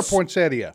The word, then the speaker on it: poinsettia: Is it poinsettia or poinsettia? – It's pronounced poinsettia, but it's spelled poinsettia.